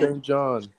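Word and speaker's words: SAINt JHN: But it's spelled like S-A-I-N is in all caps, the T is in lowercase, and then J-H-N. – J-H-N. – Okay, so. – Jin. – Saint Jin. – Saint John. Pretty dope. – Nigga's name is Saint Jin.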